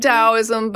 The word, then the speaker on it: Taoism: Did you say Taoism, T-A-O? – Yeah, it's pronounced Taoism, but it does, yeah, T-A-O.